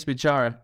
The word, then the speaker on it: Bichara: Yes, you said it correctly, Matias Bichara.